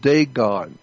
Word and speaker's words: Dagon: The Catholic Church, when you see the Pope, he represents the worship of Dagon. It's spelled D-A-G-O-N, Dagon.